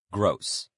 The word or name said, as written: gross